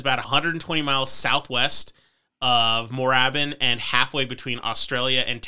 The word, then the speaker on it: Moorabbin: This is spelled Moorabbin. And for some reason, I love that word. When I saw it, I was like, Moorabbin. And I was like, that sounds amazing. And then I watched some videos and everyone was pronouncing it Moorabbin. And Moorabbin. I'm like, you're missing the opportunity with the 2 O's. – Yeah. – So it's just Moorabbin.